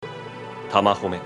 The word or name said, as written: Tamahome